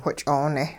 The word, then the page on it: which'oone: hupa